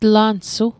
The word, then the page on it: tlansu: Mi'gmaq